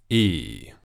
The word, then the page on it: iː: long vowel sound